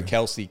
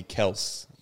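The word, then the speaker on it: Kelce: He was saying it's pronounced Kels. It's still spelled the same, but the way you pronounce it, it's Kels.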